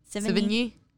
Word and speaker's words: Sevigny: Sevigny.